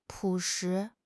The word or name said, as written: 朴实